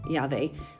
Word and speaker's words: Yahweh: Technically, the Vav there is typically pronounced V as in David, David, and other words like that. So I go with Yahweh, but it's not necessarily wrong. It's just a little dialectical change to say Yahweh.